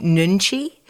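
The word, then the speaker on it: Nunchi (눈치): Carol, you said that in high school you would describe yourself as, I'm going to pronounce it correctly, Nunchi.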